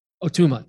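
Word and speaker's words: Ó Tuama: this benediction is from the Irish poet Patrick O'Tama, am I pronouncing that right? You're nodding, yes, because you, what's that? O'Tama, thank you. I think that's better, yeah.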